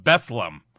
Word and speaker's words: Bethlehem: And then a man came over all the way across the stadium to tell me, sir, you're mispronouncing. It's called Bethlehem.